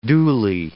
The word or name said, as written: duly